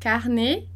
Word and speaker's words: carnet: S, T e D a gente não pronuncia no final de palavra, né? Lembrando, carnet, placard, Paris, ok? E em seguida a gente passa para France, France.